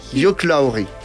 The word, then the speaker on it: Hugh Laurie: Hugh Laurie.